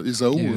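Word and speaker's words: Ésaü: On prononce Ésaou.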